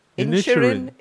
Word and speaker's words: Inisherin: It's the Banshees of Ingerheim or Ingersheim, something like that. So that's nominated. – Inisherin. – Okay, Inisherin. It's an Irish.